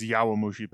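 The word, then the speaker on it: Yowamushi: Yamamushi Petal? I think I'm saying it right. – I think it's Yawamushi Petal.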